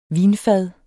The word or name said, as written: vinfad